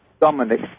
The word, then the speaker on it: Dominic: Is it Dominic or Somonic? – Dominic, D. – With a D? – Yeah.